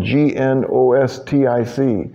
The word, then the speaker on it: Gnostic: And it's not like Scott Gnussi, where the G is pronounced, it's like Gnostic. N O S T I C, but it's got a silent G at the beginning of it.